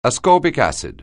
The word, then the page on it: ascorbic acid: anglais